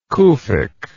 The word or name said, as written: Kufic